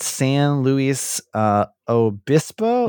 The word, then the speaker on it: San Luis Obispo: I'm going to screw this name up because I've never pronounced it before, but San Luis Obispo? – Yeah.